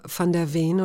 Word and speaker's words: van der Veen: Künstler heißt, ich weiß aber nicht, ob ich ihn richtig ausspreche, Christoph van der Veen oder van der Veen? Van der Veen, ja, so Holländer.